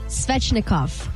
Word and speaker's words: Svechnikov: Andre Svechnikov.